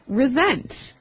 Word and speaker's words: resent: Resent. – Okay, now this is spelled exactly like resent, like with an S, right? – That's right, R-E-S-E-N-T. – But you pronounce it like a Z. – Mm-hmm. Resent.